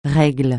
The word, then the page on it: règle: francais